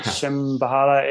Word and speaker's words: Shambhala: You can find us on Facebook or Instagram under Shambhala Farm, and Shambhala Farm's got a silent H in there. So Shambhala, Shambhala.